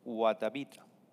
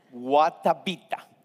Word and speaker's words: Guatavita: And they believed their God was in the deeper of a lake called Guatavita. And I want you to pronounce this word, Guatavita.